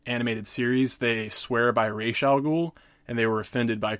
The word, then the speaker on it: Ra's al Ghul: In this scenario, he goes up against some members of the Light, chiefly Lex Luthor and Ra's al Ghul, as his name is pronounced in this iteration. I'm one of those people. I tend to pronounce it Ra's al Ghul, but I can certainly adapt to Ra's al Ghul.